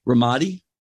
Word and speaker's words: Ramadi: So pressing on then, Ben, so you fought in, I hope I'm saying it right, Ramadi? – Correct.